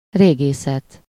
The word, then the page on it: archaeology: [ˌɑɻ.kiˈɔl.ʌ.d͡ʒi]; /ˌɑː(ɹ).kiˈɒl.ə.dʒi/; /ˌɑɹ.kiˈɑl.ə.dʒi/